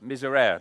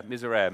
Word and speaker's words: Miserere: called, I'm going to get it wrong now, miserere. Someone will know, is that right? Has it got another syllable to it? Miserere, miserere. Mel's nodding. I'm going to take that as gospel.